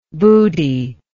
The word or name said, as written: buddhi